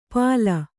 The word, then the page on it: ಪಾಲ: kannada